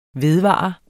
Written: vedvare

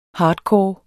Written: hardcore

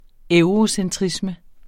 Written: eurocentrisme

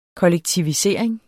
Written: kollektive

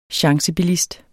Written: chancebilist

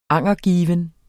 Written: angergiven

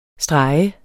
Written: STREGENDE

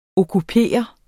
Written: okkupering

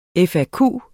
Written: FAQ

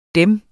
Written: Dem

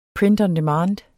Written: print

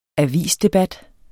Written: avisdebat

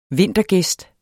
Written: vintergæst

